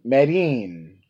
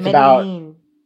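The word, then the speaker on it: Medellín: Medellin. – It's Medellin.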